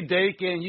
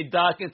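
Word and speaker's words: Daken: I say Dakin. You say Darken. Tomato. Tomato.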